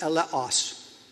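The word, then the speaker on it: eleos (ἔλεος): The Greek word that is here translated mercy in Matthew 23:23 is pronounced eleos.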